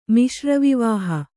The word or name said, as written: ಮಿಶ್ರವಿವಾಹ